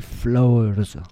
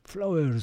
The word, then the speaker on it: Flauers: Et là, on va s'en écouter une reprise récente, attention, en italien, par Tony Borlotti et Isui Flowers. Flowers, c'est marrant, ça s'écrit F. L-A-U-E-R-S.